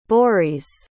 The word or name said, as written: borys